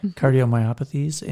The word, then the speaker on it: cardiomyopathy: And of course, long term, you get a lot of issues with liver disease, cardiomyopathy. Did I just say that wrong? – Yep. – Cardiomyopathies and, you know, stroke and such, pancreatitis.